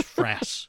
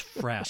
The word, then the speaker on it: frass: It's frass. It's frass. Say it correctly.